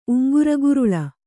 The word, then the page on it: ಉಂಗುರಗುರುಳ: uŋguraguruḷa